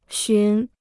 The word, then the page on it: 寻: xún